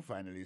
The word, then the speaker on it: finally: Finally, c'est F-I-N-A-L-L-Y, finally. T'as des prononciations, oui? Finally. Accent tombé sur le premier syllabe. Finally. F-I-N-A-L-L-Y.